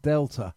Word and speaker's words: D3LTA: That's spelled D3LTA, but pronounced Delta.